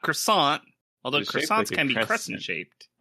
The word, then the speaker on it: croissant: Croissant. There's a T in there, but you don't say it.